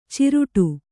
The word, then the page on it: ಚಿರಡಿ: kannada